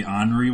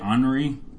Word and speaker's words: ornery: I'm gonna be the ornery one. Is that how you say it? Ornery? The disgruntled ornery. – Ornery. Oh yes, I said more like give me honor.